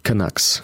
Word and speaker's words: Canucks: Was die, wie wird die Vancouver-Mannschaft ausgesprochen? Canucks? – Canucks. – Was hier erstmal klingt wie Kanacken.